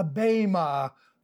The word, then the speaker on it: Bema: Obviously, "for we shall all," here we have Paul including himself, "we shall all stand before the Bema," the Greek word B-E-M-A, the E is pronounced as a long A, Bema, the judgment seat of Christ.